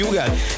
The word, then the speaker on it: Hugel: Így van, az úriember, akinek minden országon másképp ejtik a nevét. Van, ahol Uzsel, van, ahol Hügel, van, ahol Ugel, meg mindenféle kép, de nálunk úgy mondjuk, hogy Hugel, és Szálltó, és a Jamaican Bam Bam érkezik, a csavarjátok fel a hangerőt!